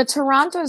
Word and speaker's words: Toronto: The Toronto Zoo. Someone from Canada recently told me that in Toronto, they don't pronounce the second T. So I'm going to say it, hopefully, like a local.